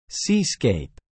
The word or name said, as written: seascape